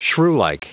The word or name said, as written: shrewlike